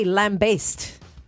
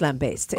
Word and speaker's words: lambaste: And word of the day, lambaste. – Oh. – Lambaste? Have you heard of it? – Not lambast. – No, it's pronounced lambaste.